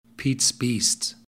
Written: peats beast

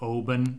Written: oban

